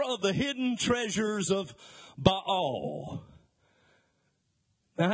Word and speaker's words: Baal: You know, I heard it called Baal all of my life, but the correct pronunciation of that Hebrew word is Baal.